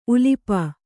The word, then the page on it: ಉಲುಪಿ: kannada